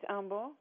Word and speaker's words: Humble: You pronounce it Humble or Humble? – It's Humble.